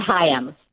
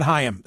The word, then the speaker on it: Higham: How does Scott pronounce his last name? Scott Higham. Scott Higham.